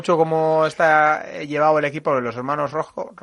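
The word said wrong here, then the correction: Roodhooft: siempre lo digo mal. – Sí, Rojhoff.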